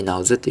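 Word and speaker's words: 脑子: えー、脳という字にですね、子供という字を書きまして、えー、発音はですね、えー、脳という字がナオというふうに発音します。えー、というのは子供の子という意味で、えー、ナオズというふうに言います。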